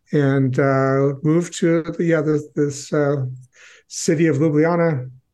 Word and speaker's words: Ljubljana: Ljubljana. – Ljubljana. Yeah, I wasn't even going to try and pronounce the city. Ljubljana, Slovenia.